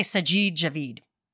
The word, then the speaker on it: Javid: I'm going to say Sajid Javid. That's what I'm going to say. I'm going to put the emphasis on the last syllable.